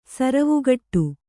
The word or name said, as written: ಸರುವುಗಟ್ಟು